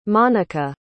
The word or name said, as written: monica